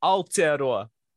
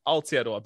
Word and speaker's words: Aotearoa: Um, Aotearoa is the name. Aotearoa, but beautiful pronunciation, beautiful, beautiful attempt. It's a difficult one. There's a lot of uh, different um, things about Maori pronunciation and how we blend our vowels together and drag out certain things.